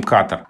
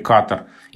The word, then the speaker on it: Катар: После того, как в этой стране было принято решение, что пройдет чемпионат мира по футболу, и это название стали употреблять чаще, с таким ударением «Катар», оно как-то так вот больше закрепилось в речи, и, собственно, словарь имен собственных отдает предпочтение ударению «Катар». И сейчас тоже можно часто встретить ударение «Катар».